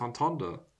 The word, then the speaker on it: entendre: No, because it's a French word. So it's entendre. – Entendre. – There's still the R because the E is at the end.